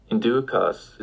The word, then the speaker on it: inducas: Inducas is the subjunctive form of inducere. Note that the vowel changed to a long a.